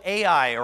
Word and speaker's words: Ai: So they succeed, like, really, really good in Jericho. So they go to the next place, and this next place is called Ai, or I. It's spelled Ai, literally.